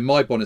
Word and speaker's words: Myebon: It's just, so Mai Bon is spelled M-Y-E-B-O-N.